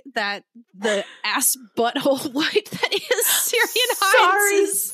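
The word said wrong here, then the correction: Ciarán: His name is actually pronounced Kieran.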